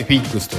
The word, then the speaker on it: FIGGS: FIGGS と書いて f i クスと読みますえー、